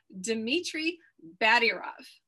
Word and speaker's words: Dmitry Badiarov: Today, my guest is, make sure I pronounce this correct, Dmitry Badiarov.